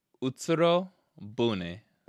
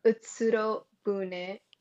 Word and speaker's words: Utsuro-bune: Like, she would love to be in a movie about this Utsuro-Bune, or how do you say it? – Utsuro-Bune. – Utsuro-Bune.